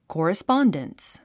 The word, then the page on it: correspondence: kɔ̀ːrəspάndəns; コーラスパンダンス